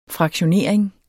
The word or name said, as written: fraktionering